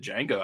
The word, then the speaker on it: Django: But he's spelled with the D, with the proper D in the front, which is silent. – Mm, gotcha. Gotcha. – After Django Reinhardt.